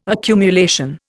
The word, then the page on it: accumulation: Indian